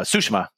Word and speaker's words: Tsushima: Tsushima, sorry to pronounce it correctly, or at least how they say it in the game.